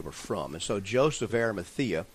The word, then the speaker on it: Arimathea: Joseph of Arimathea. – And that's exactly right. – And that's exactly right. You said it right, and that's where he was from.